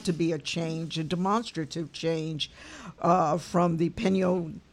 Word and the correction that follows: Peña Nieto: I keep doing that. I am not pronouncing it correctly. Help me, Laura. – The Peña Nieto.